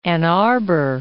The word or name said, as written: Ann Arbor